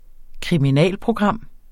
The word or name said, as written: kriminalprogram